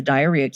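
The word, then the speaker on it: Dierya: It's spelled D-I-E-R-Y-A. I've mentioned it on the show before. It's the diarrhea keyboard.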